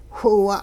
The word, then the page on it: whiwa': hupa